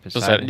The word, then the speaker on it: Poseidon: Poseidon.